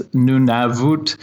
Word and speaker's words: Nunavut: Um, I guess kind of the key to anything in the Inuktitut language uh, is there are three core vowel sounds. So anything with a U is an U sound, anything with an A is an A sound, anything with an I is an E sound. So it's Nunavut, it's uh, Inuktitut is the language.